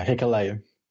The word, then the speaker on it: Hikuleo: And we moved into the second match, which was Kota Ibushi and Juice Robinson against uh, Takahashi and uh, Is that how you pronounce his, his, name? I've only seen him a couple of times. – Yeah, Hikuleo. There yeah. you go.